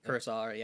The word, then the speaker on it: Kurosawa: Kurosawa, yeah.